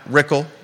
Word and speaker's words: Rickle: Albemarle County has got Ann Malik and Brad Rickle, rhymes with pickle, contested election.